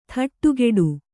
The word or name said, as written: ಥಟ್ಟುಗೆಡು